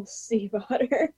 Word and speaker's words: Stiefvater: Steve, like the name. That's all I remember. Otter, like the animal, Stiefvater.